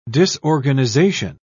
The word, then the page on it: disorganisation: British English